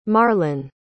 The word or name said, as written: marlon